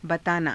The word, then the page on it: bataanaa: hindi